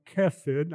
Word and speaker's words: hesed: It's transliterated H-E-S-E-D or hesed. It's actually pronounced kesed. I can't say it right, but there's a guttural sound in front of it, kesed.